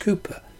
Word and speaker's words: Cowper: There may be deep thinkers like Thomas, men of depressed nature like William Cowper, spelt Cowper, I think pronounced Cooper.